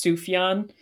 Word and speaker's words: Sufjan: I believe it's pronounced Sufjan, Sufjan Stevens, and the album Michigan, when it first came out, obviously being in Michigan, it was really huge.